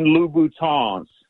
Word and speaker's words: Louboutin: And then most importantly, so the women that listen to the show don't start tagging you on YouTube, they are Christian Louboutins. You got to pronounce it with the French Louboutin.